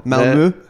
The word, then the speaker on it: Malmö: Katie works for a company that has its headquarters in Sweden, just outside Malmö. And their parent company, their investors... – Someone's worked out how to pronounce it. – There were a couple of raised eyebrows coming this side of the table. Malmö.